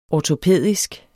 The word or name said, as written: ortopædisk